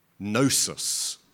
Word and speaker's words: gnosis: I always get thrown by the silent G. I mean, sometimes you say it, sometimes you don't. It's weird because the gnosis is knowledge, but we talk about Gnosticism.